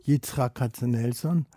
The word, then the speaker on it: Yitzhak Katzenelson: Yitzhak Katzenelson.